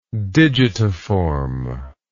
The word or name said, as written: digitiform